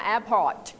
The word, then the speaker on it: Airport: Airport มีตัว T ข้างหลังด้วยค่ะ Airport